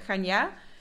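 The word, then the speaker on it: Chania: Ehm, de stad waar ik woon heet uh, eh, Gania. Eh, zo spreek je dat uit met de klemtoon op de laatste A.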